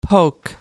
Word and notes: poke: American English

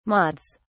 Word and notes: mods: British English

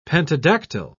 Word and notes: pentadactyl: British English

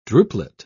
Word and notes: drupelet: British English

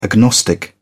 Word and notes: agnostic: British English

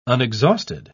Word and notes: unexhausted: British English

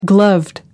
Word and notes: gloved: British English

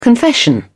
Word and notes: confession: British English

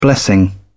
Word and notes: blessing: British English

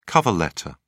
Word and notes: cover letter: British English

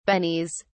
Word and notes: bennies: British English

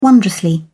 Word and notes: wondrously: British English

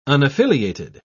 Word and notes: unaffiliated: British English

